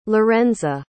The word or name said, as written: lorenza